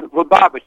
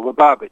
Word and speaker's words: Lubavitch: Now, I've gone by, say, a Lubavitch Center during the high holidays, and they're singing Habanagula. – Yeah, yeah. Well, actually, that's pronounced Lubavitch. The accent is on the second syllable, Lubavitch.